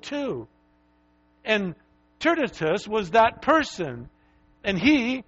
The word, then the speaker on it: Tertius: I think that's the way you say his name. It's T-E-R-T-I-U-S. I think it's Tertius. That's close enough, right?